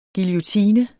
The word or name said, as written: guillotine